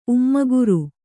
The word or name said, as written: ಉಮ್ಮಗುರು